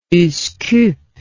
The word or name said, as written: Uskub